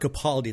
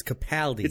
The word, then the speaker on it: Capaldi: Which is correct. It's not Capaldi. It's Capaldi.